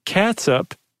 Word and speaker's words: catsup: So ketchup, K-E-T-C-H-U-P. – Yep. – Versus catsup, C-A-T-S-U-P. – Weird. – The How Stuff Works article, I didn't see this anywhere else, is that they're pronounced the same.